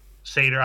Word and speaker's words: Satyr: Satyr is how it's pronounced.